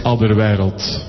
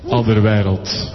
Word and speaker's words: Alderweireld: In Belgium it's Alderweireld. – ¿Cómo? – Alderweireld. – Alderweireld.